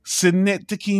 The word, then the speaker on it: Synecdoche: And on the last discussion, we spoke about a movie name I'm about to butcher for the 19th time, Synecdoche, New York. Am I saying that right, James? – That is correct.